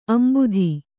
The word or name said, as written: ಅಂಬುದ